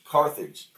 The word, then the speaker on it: Carthage: Cartilage, cartilage versus Tim. I don't know how to pronounce it. Not cartilage, Carthage. – Carthage.